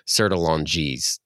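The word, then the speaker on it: Sertillanges: It's The Intellectual Life by, I'm going to mispronounce this because he's French, Antonin Sertolange. That's Sertolange, or maybe it, if you were to pronounce it like an American, it's Sertolanges.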